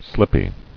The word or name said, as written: Slippy